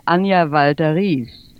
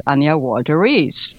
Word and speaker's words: Anya Walter-Riess: Well, in German, I would say Anya Walter-Riess. And in English, of course, Anya Walter-Riess.